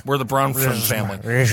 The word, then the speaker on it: Bronfman: It's literally Bronfman. And I'll tell you what it is. It's that F. They stick right in the middle. Bronman? Got it. Bronfman.